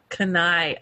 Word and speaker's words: Kenai: Kenai, Alaska, not Kenai, Alaska, as I've pronounced it wrong multiple times on the podcast.